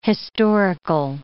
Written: historical

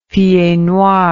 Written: pied noir

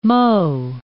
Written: mot

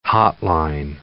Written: hotline

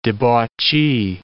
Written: debauchee

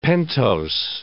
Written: pentose

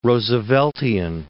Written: Rooseveltian

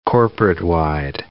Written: corporate-wide

